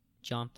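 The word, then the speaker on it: Gian: Follow me on Instagram at GIANFACIO, John Facio, because my name be spelt weird. But it's just pronounced John because we're not in Italy.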